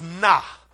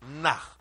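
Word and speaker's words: nah (נָא): Now, the word "now" in the Hebrew is "nah," n a, nah. That's how it's pronounced in the Hebrew tongue.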